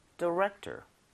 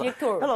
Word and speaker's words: director: But now I'm a movie director. – T-O-R. 디렉토리구나, 디렉톨. – 근데 발음은 디렉터 맞는데 너무 톨은 아니고요. – 그렇게 외워야지 근데 나중에 또 안 틀려요. – 그랬다가 어디 가서 디렉톨.